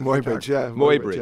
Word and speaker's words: Muybridge: Why in your book, is it Muybridge? Is that how you pronounce it? – Muybridge.